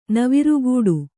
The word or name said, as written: ನವುರುಕಟ್ಟೆ